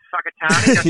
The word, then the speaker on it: Whakatāne: It's Whakatāne, yep. And it's actually spelt with a WH.